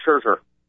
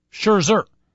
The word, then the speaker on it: Scherzer: White Sox opener taking on the Detroit Tigers pitching matchup, Jake Peavy against Max, how do you pronounce it, Scher or Scher? – Max Scherzer. – Scherzer. – Yes.